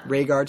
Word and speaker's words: Rhaegar: But we also know that the sister was, before she was murdered by the Targaryens, was raped by Rhaegar. Is that how you say his name? – Yeah. – Rhaegar Targaryen.